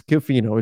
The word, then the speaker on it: Schifino: and it's Schifino.